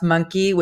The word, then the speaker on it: Monke: Um, my name is Audrey, and my actually my last name is pronounced Monkey, which is oh, crazy.